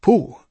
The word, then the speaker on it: Pooh: Pooh, by the way, is pronounced sort of like poo, but without so much oo, like the sound you make when blowing a bug off your arm on a hot summer day.